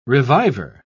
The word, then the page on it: reviver: British English